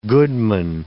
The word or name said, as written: goodman